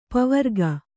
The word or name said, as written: ಪವರ್ಗ